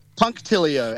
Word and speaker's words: punctilio: Say it again. A pontilio or a punctilio? – Punctilio. And punk.